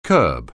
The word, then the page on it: curb: anglais